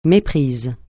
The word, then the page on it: méprise: francais